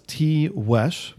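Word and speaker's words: Tiguex: Tiwesh is pronounced as Tiwesh because the Spanish G is pronounced as an English W.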